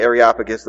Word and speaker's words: Areopagus: And it's pronounced a couple of different ways, but I usually say Areopagus.